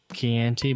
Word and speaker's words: Chianti: I think it's actually pronounced Chianti, but whatever. I know that it's spelled like Chianti.